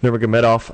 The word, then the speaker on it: Nurmagomedov: First thing I'm thankful for are UFC fighters like Conor McGregor, Mark Hunt, Khabib, Nurmagomedov.